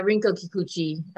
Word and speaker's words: Rinko Kikuchi: Yeah, Rinko Kikuchi.